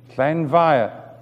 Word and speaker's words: Llanfair: F in Welsh is pronounced V, Llanfair.